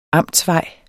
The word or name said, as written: amtsvej